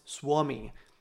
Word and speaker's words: Suomi: In Finnish, Finland is pronounced Suomi, which means the land of bogs or swamps.